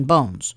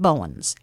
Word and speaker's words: Bournes: Now, it was B-O-U-R-N-E-S. They were all seafaring men. Always pronounces it just plain Bones. Bones Landing. And the people along there pronounced it Bowens, and that's a fact.